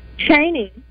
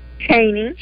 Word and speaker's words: Chaney: Chaney. – Is it? – Say it again. – Chaney.